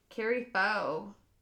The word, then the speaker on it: Faux: F-A-U-X. – Kari Foe. – Kari Foe. Oh, that's right. – I think. – Kari Foe, that's right.